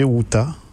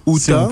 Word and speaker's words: Ceuta: Je pense que c'est Ceuta. – Ceuta. – Si on peut le prononcer en espagnol, en espagnol. – Ça fait Ceuta.